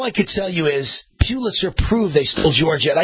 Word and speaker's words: Pulitzer: Well, I got it right this time, Chris. Pulitzer. Because Chris said the way you pronounce it is like a church pew. P-E-W. Pulitzer.